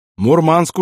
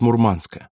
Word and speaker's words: Мурманск: Дав офицеру прикурить, сказал весело: «Мурманск уже скоро!» Он сказал это на местном наречии, делая ударение на втором слоге, и это решил запомнить Пеклеванный.